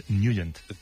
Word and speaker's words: Nugent: Pero la pronunciación correcta es Nugent.